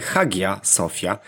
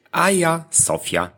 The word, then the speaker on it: Hagia Sophia: Tak więc najczęściej popełniany błąd to wymawianie Hagia Sophia. Nie jest tak. Prawidłowa wymowa obejmuje nie mecha, więc mówimy Hagia Sophia.